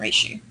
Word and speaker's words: Reishi: Reishi? Is that how you say it? – Which one? – The mushroom reishi. Right? – Oh, yeah. Reishi. By the way, this looks like a nice whiskey bottle, but it's actually coffee in sachets.